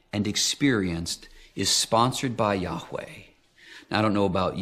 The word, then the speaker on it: Yahweh: Now, in Hebrew, "I am" is pronounced Yahweh.